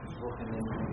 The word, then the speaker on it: Wochenende: Yo no sé alemán, entonces yo lo diré como ahí está escrito, que es Wochenende, si alguien... ¿Alguien sabe cómo se pronuncia en realidad? – Wochenende. – Muy bien, excelente. Entonces, está bien pronunciado.